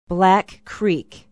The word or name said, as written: black creek